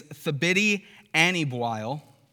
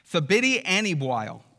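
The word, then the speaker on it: Thabiti Anyabwile: pastor, council member of the Gospel Coalition, and Nine Marks author, his name is Thabiti Anibwail. I had to look up how to pronounce that, by the way. Right? Thabiti Anibwail is his name, and he had this to say.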